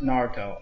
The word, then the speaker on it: Naruto: Okay, first of all, it's pronounced Naruto, okay? – Oh, whoa.